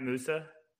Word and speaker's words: Moussa: Did I say his name right? Musa? – Yeah.